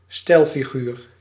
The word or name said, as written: Redewendung